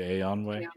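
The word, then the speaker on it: Eönwë: Is it Aeonwë? – Yeah.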